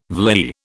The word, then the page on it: vlei: British English